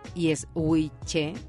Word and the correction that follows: Ouiche: no, Ouiche y Lorene, como Lorena, pero Lorene.